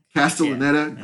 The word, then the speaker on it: Castellaneta: Castellaneta, yeah.